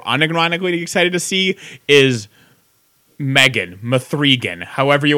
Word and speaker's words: M3GAN: It's M3GAN, and I still call 7 Sesevenin because if you're going to spell your movie S-E-7-E-N, I'm going to pronounce it Sesevenin because I'm an asshole. But yes, it's just called Megan, but the E's a 3. So if you're Googling it, you should spell it Mithrigan, but it's pronounced Megan.